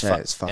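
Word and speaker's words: Whaka: Which I believe is actually the Fucker 100. – Oh, yeah, because WHA is Fucker. – And it's Fucker Weta Weta Forest. So. – Okay, yeah. So we've been saying it wrong the whole time.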